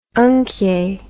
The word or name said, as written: unkjay